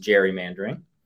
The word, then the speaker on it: gerrymandering: Nick Seabrook is an authority on constitutional and election law and an expert on gerrymandering, sometimes pronounced gerrymandering.